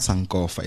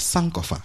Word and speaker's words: Sankofa: which is how you actually pronounce that word. It's not Sankofa, it's Sankofa. And she's made it quite clear on her social media pages. So if you are trying to, you know, get it right, I think that's the right word to use. That's exactly how it's pronounced.